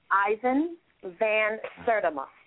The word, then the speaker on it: Ivan Van Sertima: Ivan Van Sertima. – Can you spell that? – S-E-R-T-I-M-A is the last name. It's Ivan, I-V-A-N-V-A-N, Van Sertima.